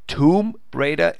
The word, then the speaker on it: Tomb Raider: Fakt ist, du hast recht, ja, der korrekte Engländer würde nach der Schreibschrift dieses Wort, das geschrieben wird, T-O-M-B, aussprechen wie Tomb, T-H-U-M, als Lautschrift, Tomb Raider, das ist richtig. Aber dieses Spiel, seit vielen, vielen Jahren auf dem Markt, hat sich weltweit so etabliert, dass beide Aussprachen korrekt sind. Tomb Raider ist genauso korrekt wie Tomb Raider.